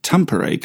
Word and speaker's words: Tampere: Now, Rika, did I pronounce Tampere correctly? – You did, yes.